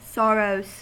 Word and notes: sorrows: American English

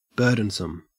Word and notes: burdensome: Australian English

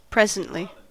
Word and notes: presently: American English

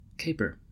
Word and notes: caper: American English

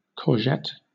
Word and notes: courgette: British English

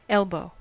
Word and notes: elbow: American English

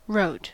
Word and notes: wrote: American English

